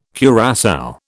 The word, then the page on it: curassow: British English